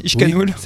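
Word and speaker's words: Ixcanul: Ishkanul le volcan, je prononce bien Boris, Ishkanul. – Oui, c'est bien ça. – C'est à la basque, c'est ça, les X se prononcent Ish.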